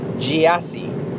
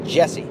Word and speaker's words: Gyasi: It's like G-Y-A-S-I, Jesse, but they say Jesse. Just like this Jussie Smollett, J-U-S-S-I-E.